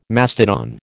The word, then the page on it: mastodon: American English